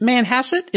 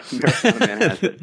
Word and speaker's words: Manhasset: Is it Manhasset? Am I saying that right? – That's right. – Manhasset Concours. Manhasset. There we go.